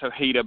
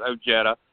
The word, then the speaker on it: Ojeda: There's a state senator running for Congress, a Democrat, Richard Ojeda, and most times you see it's Ojeda, but he pronounces it Ojeda.